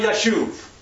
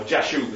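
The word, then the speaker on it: Jashub: Shia Yashuv, or Jashuv as we pronounce it.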